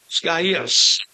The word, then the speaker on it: Gaius: And having caught Gaius, Gaius. I'm going to say that. I'm going to put three syllables. Gaius.